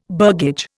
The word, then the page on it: burgage: Indian